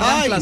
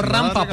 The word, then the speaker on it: rampa: No se dice Rampla, sino Rampa, por favor.